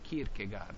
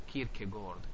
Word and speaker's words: Kierkegaard: Come a molti di voi sarà noto, chi è amante di letture filosofiche, all'inizio dell'Ottocento, dalle parti della Danimarca, con Kierkegaard, o Kierkegaard, come volete pronunciare, che l'angoscia viene collegata alla condizione esistenziale in cui noi ci troviamo.